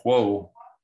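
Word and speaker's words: Juh: And they were the band of Huo, which is how I choose to pronounce it. It's J-U-H, but a few people disagree on the pronunciation.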